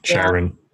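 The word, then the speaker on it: Charon: You know the coin in the mouth that they put in Sharon's, or however you pronounce his name? – Right. Sharon.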